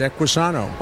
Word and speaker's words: Equisano: Well, the product that that you are here to talk about today, Equisano. Am I saying it correctly? – You're up. Sano means health in Spanish. Equi for horse, Equisano.